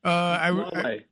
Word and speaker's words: Huawei: And what about the, what is it, Mawa? Am I pronouncing it correct? The phone company? – Huawei.